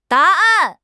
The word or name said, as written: da2 an4